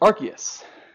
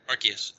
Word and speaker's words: Arceus: Arce- How do you pronounce it? Arceus. – Arceus.